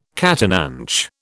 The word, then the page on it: catananche: British English